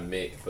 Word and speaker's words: Mick: It's an I. It's M-I-C. – Oh, okay. Yeah, oh, yeah. Mick Jenkins. – Yeah, okay. – Like Mick. Like, not Mick, but Mick. – Yeah, I got it. Spelled like Mike. – Spelled like Mike, but it's Mick.